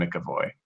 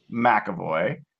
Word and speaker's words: McAvoy: First of all, you cannot sound credible as a critic if you're going to call him McAvoy. It's not. – Oh, did I say it wrong? – Yeah, so it's Tanner McAvoy.